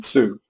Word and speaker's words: Hsu: how do you pronounce his name? Sue. Sue, spelled H-S-U.